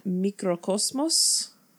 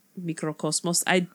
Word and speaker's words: Microcosmos: Microcosmos? – Yes. – Yes. – I can't help but roll the R. I'm not even like Microcosmos.